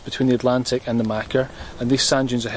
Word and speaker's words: machair: So you then end up with this fertile plain, which is the Macca, which is spelt M-A-C-H-A-I-R, which is a good Gaelic word meaning fertile plain.